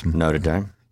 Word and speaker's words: Notre Dame: Notre Dame? – Yeah. – Notice I pronounce it the way the locals pronounce it.